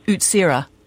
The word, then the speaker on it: Utsira: Well, it was split up some time ago, but it's spelled actually Utsire. So it looks like Utsire, but it's Utsira.